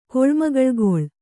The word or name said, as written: ಕೊಳ್ಮಗಳ್‍ಗೊಳ್